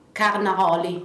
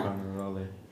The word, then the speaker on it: Carnaroli: It's carne aioli. – Say it again. – Carne aioli. – How do you spell that? – C-A-R-N-O-L-I. Carnaroli. – Okay, yeah. – Yeah. – Carnaroli.